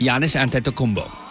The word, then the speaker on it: Giannis Antetokounmpo: Giannis Antetokounmpo. – You know what? That's actually pretty close. – Yeah? – It's not bad. – Okay, go ahead, Mr. Showoff. How do you say it? – Giannis Antetokounmpo.